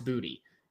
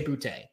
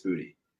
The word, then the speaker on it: Boutte: Listen, I understand that the name is French. We are not speaking French. If he, like, there are, I think, four towns in the U.S. spelled the exact same way. All of them are pronounced Boutte. If we were speaking French, we would say Boutte, but I'm going to go with what Kayshon said.